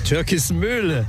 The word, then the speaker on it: Türkismühle: Türkismühle. – Türkismühle. Die Betonung ist anders.